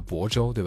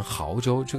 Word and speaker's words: 亳州: 但是像这种名字当中有生僻字的确实是少数情况，我印象特别深刻的是有一个城市叫亳州，对吧？但是这个亳字长得太像毫米的那个毫了，所以很多人经常会念成亳州，这个就导致人家去宣传亳州的很多产品啊，包括旅游，包括一些什么酒啊，就必须得在后面加一个括号亳啊，汉语拼音教你怎么念啊，这个确实给宣传会带来一定的麻烦。